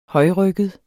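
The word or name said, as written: højrygget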